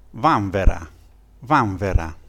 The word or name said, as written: vanvera